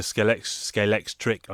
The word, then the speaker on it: Scalextric: And did some research, like I said, I think on the last episode, Carrera or Scalextric, and I went for Scalextric, I should say, as many people pronounce it wrong.